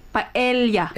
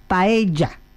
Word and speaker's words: paella: The two L, it's when you say the two L's, it's ya. – but I am pronouncing as here we pronounce in Mexico and in Spain, or in Spanish means, or Castellano, we say paella.